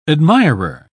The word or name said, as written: admirer